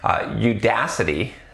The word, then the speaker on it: Udacity: About a year and a half in, I decided to enroll in Udacity. See, I said it right. I didn't say Udacity. I said Udacity Nano degree for Android development, despite their prerequisite being 2 years of Java.